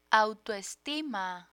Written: autoestima

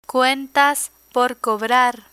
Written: cuentas por cobrar

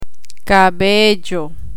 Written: cabello

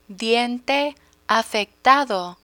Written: diente afectado

